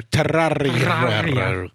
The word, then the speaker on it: Terraria: You can pronounce that however you can roll the R. Terraria.